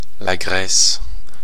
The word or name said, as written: Grèce